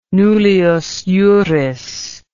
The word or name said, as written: nullius juris